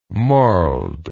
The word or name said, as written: marled